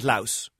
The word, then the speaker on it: Laos: So the first thing, it, you know, it's fine to call it Laos. It's spelt L-A-O-S. But in the country itself, they don't use that S. So we'll try not to be using it.